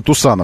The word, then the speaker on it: Tucson: Он называется Тусан автомобиль. Хотя пишется иначе.